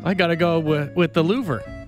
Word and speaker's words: Louvre: The Louvre? Did I say that right? – The Louvre, yes, you did. – You said it better than I just said it. The L Museum, whatever it's called. The L-O-U-V-R-E Museum. – The Louvre. – That's right, the Louvre. – The Louvre. – Rhymes with a vacuum company.